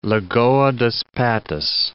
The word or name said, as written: Patos, Lagoa dos